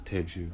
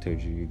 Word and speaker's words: Teju: Most people know me as Teju, and you know, it all goes any way you pronounce it. Teju, if it's Teju, if it's Teju, if it's anyone, as long as you're calling my name, I don't have a problem.